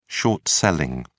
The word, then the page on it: short selling: British English